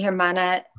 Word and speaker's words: hermana: Mi hermana se llama Carrie. – Hermana. – Mi hermana. – So H, the H is silent.